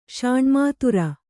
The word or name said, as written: ಷಾಣ್ಮಾತುರ